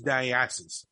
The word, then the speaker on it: Dionysus: They used the New Year's baby as a symbol of Dionysus, and everybody does, you know, different ways of saying Dionysus. But the way that I've known to pronounce it is Dionysus, and it's the god of wine.